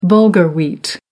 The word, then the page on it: bulgur: American English